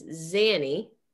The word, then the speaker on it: Zanny: She constantly referred to her as Zanny, short for Zenaida, and rhyming with nanny, which just makes me want to set my kitchen on fire.